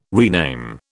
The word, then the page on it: rename: British English